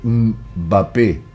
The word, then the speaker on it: Mbappé: una persona conocida, cercana a mí, dice, che, ¿cuántas patadas le pegaron a MP Mape? No se dice así, se dice Mbappé.